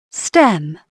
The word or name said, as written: stem